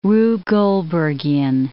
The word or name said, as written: Rube Goldbergian